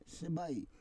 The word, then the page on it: sebai: Roon